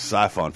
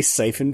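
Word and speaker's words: Syphon: Are you ever going to say siphon filter correctly? – What did I say? – Siphon. – How do you say it? You say it. – Siphon. Like I'm siphoning gasoline out of a tank.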